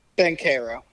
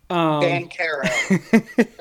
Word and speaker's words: Banchero: Now, I will say, I think I'm going to pronounce his name right, Pablo Blanchero. – Banchero. – Blanchero. – Banchero. There's no L.